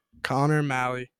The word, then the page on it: Connor Malley: male